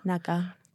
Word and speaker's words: Naga: Naga. – Which, yeah, I think that's also the pronunciation because it's spelled with a kaf, but as Saudis would pronounce it.